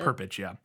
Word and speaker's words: Perpich: I don't know how to, it's P-E-R-P-I-C-H. – That's how I would pronounce it. – Perpich, yeah.